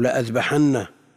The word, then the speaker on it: لأذبحن: وقد تزاد الألف في الرسم لكنها لا تنطق. والحسن البصري يجعل هذا مثل لأذبحن، ألف زائدة في الرسم.